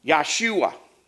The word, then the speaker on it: Yahshua: Now, the name Joshua would have been correctly pronounced Yahshua. They have no J in the Hebrew language.